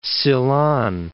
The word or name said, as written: Ceylon